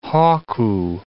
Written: hokku

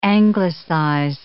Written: anglicize

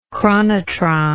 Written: chronotron

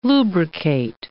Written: lubricate

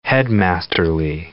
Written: headmasterly

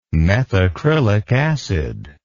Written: methacrylic acid

